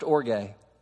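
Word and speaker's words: orge (ὀργή): The second Greek word for wrath, which is more common, is the Greek word pronounced orge.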